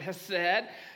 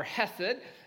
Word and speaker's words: hesed: It's the word hesed, or hesed.